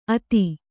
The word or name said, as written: ಅಥ